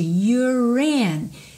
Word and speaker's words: you're: You're in. Se you contraído com R é your, e o E não é pronunciado, aqui eu vou ligar então o R direto no in.